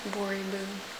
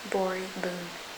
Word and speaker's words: Boriboune: Bori Boone. Bori Boone. – Can you spell that? – Yeah, it's B-O-R-I-B-O-U-N-E. – B-O-R-I-B-O-U-N-E.